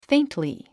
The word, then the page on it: faintly: féintli; フェイントリィ